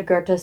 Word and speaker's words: Goethe: That, I'm pretty sure that's exactly how you say it is Goethe.